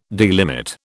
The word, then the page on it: delimit: British English